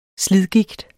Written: slidgigt

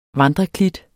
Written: vandreklit

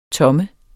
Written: tomme

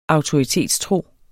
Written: AUTORITETSTRO

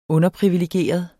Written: underprivilegeret

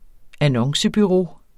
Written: annoncebureau

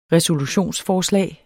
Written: resolutionsforslag

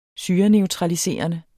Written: syreneutraliserende

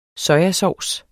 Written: sojasovs

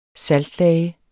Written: SALTLAGER

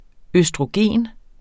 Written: østrogen

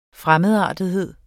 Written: fremmedartethed

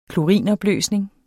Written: klorinopløsning

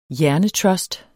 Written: HJERNETRUST

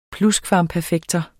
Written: PLUSKVAMPERFEKTER

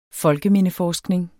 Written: folkemindeforskning

